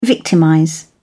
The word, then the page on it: victimize: British English